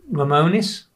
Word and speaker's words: Lemonis: Is it Lemonis? – Yeah.